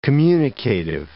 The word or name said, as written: communicative